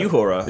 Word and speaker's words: Uhura: Yeah, I mean, how do you say Uhura? – It's actually Uhura.